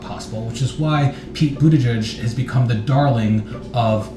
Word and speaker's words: Buttigieg: It's Buttigieg, and it's Buttigieg.